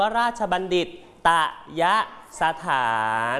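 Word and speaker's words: ราชบัณฑิตยสถาน: ราชบัณฑิตตยะสถาน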